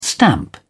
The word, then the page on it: stamp: British English